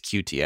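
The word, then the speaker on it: Coutee: Because before this trade, you know, there was obviously Will Fuller and Kiki QT. I don't know how to say that. I think it's QT actually. I've been saying it wrong the whole time, but both of those guys, Will Fuller and QT, are very talented.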